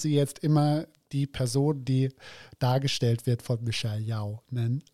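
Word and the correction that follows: Yeoh: Aber Michelle ist, Yo sagt man Yo. einfach nur.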